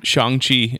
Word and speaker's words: Shang-Chi: Shang-Chi and Legend of the Ten Rings. – Shang. – Okay. Yeah. So yeah. Shang. So and then the order goes, it goes Black Widow, Shang-Chi, Eternals, then Spider-Man No Way Home in December.